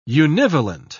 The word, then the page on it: univalent: British English